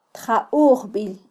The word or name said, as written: tjaujbil